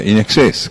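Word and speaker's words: INXS: Se pronuncia In Excess, claro, en exceso.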